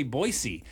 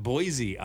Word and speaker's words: Boise: Boise, Idaho is another one. They say Boise. Idaho, not Boise, Idaho.